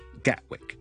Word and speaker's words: Gatwick: 例如好出名嘅倫敦嘅其中一個機場叫做 Gatwick G A T W I C K。